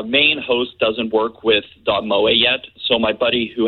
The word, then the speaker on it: moe: Dot M-O-E? – Yeah. – Dot Mo? – It's pronounced Moe, but yeah.